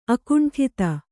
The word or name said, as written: ಅಖಂಡತೆ